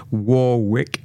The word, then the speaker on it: Warwick: Again, because we know that her name's pronounced differently in different places. Warwick in America, Warwick in England.